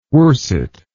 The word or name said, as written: worset